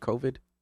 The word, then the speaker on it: COVID: Did I pronounce it right? COVID? – That's correct.